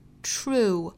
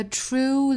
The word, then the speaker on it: true: 那美音部分呢，我们来看，首先是 leader，美音读 leader，前面的 true 同样是要注意不要读 true，应该是 t r tr true rue true，要听到里面的这个 rue 的声音才对。 A true leader， a true leader avails himself， avails himself，跟刚才英音是一样的发音， avails himself of the wisest， of the wisest counsel he can。